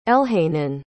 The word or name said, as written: elhanan